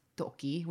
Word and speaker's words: Doki: But after he left Someday, Rado put out an album called It's We with a rapper named Toki, which is spelled D-O-K number two. – I've definitely on this show pronounced that Dok too before. – I think I have too, but it's Doki, which fair means enough rabbit.